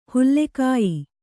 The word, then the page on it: ಹುಲ್ಲೆಕಾಯಿ: hulle kāyi